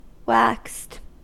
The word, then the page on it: waxed: American English